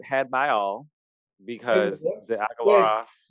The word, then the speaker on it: Agalarov: I think that's how you pronounce it, Agalarov. – I think so. That sounds right.